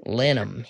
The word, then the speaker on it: Lanham: Is that how you say it? – Lanham, yep.